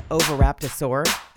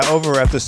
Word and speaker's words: Oviraptor: I always want to put saurus at the end, so I have found that I say it wrong, as though I'm going to complete the word with saurus, but I don't. It's just Oviraptor.